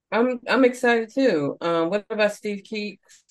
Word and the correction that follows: Klee: It's actually pronounced Klee.